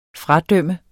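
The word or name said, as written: FRADØMME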